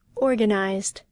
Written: organized

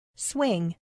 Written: swing

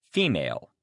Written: female

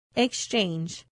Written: exchange

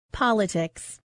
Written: politics

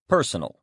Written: personal

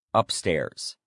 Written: upstairs

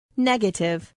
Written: negative

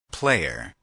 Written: player

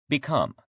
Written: become

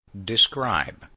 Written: describe

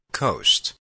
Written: coast

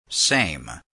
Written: same

